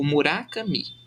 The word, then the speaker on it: Murakami: Eu cheguei a pesquisar a pronúncia do nome dele e me parece que, que tem duas sílabas tônicas, que é tipo Murakami a pronúncia correta, mas eu não vou falar dessa forma, provavelmente.